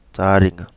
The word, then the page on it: Taringa: Kapingamarangi